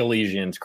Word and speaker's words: Elysian: Well, how do you pronounce it? Elysian. I always hear it and then Elysian. I never remember. Elysian. Yeah. It's not exactly how you think it is, but I think Elysian's correct.